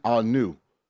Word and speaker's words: Aune: And I hope I'm saying his last name right. Austin Aune. – A-U-N-E. – Yes, A-U-N-E is how you pronounce the North Texas quarterback.